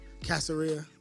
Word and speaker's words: Caesarea: Caesarea.